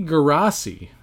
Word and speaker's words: Geraci: So this guy here, Sonny Garaci, I believe that's how you pronounce it, G-E-R-A-C-I. He had a hit in the 60s and a hit in the 70s.